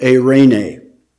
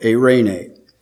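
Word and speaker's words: eirene (εἰρήνη): The word peace, literally, in Scripture, in the original language, is the word eirene. That's the word, eirene. And it is, I'll spell it in English letters for you, E-I-R-E-I-N-E-I, eirene.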